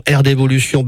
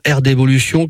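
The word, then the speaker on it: R'D'évolution: C'est le cas de notre invité de ce mercredi 26 août, Sébastien Pichot, administrateur de l'association Air d'Évolution basée au Vigan.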